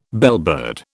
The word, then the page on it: bellbird: British English